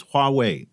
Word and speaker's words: Huawei: So just for the record, the way you pronounce it is Huawei, you know, two characters, Hua and Wei.